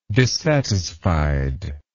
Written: dissatisfiedness

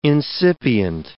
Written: incipient

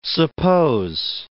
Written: suppose

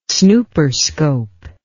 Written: snooperscope

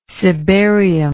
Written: cibarial

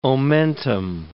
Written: omentum

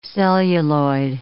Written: celluloid

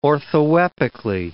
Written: orthoepically

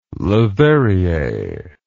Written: Leverrier

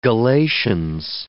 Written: Galatians

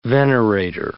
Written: venerator

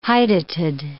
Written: hydatid

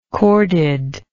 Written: corded

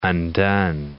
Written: undone

